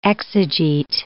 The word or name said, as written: exegete